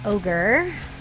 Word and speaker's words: Ohgr: And then before Waitress, we heard Ogre out of L.A. – That's how you pronounce that. – Yeah, Ogre with their song Freaky. – Yeah, it's spelled O-H-G-R.